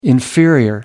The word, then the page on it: inferior: American English